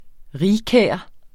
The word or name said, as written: rigkær